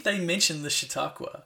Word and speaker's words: Chautauqua: So in 2013, you started the Chautauqua, is that correct? – Perfect.